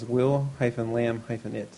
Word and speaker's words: Willamette: He has Will-Lam-It, so I'd say Willamette.